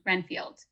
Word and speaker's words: Renfield: Renfield.